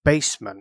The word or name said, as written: basement